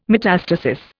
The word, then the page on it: metastasis: Indian